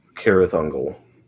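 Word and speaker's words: Cirith Ungol: In Elvish, that C is a hard C. – Oh, am I saying it incorrectly? – Cirrus Ungol.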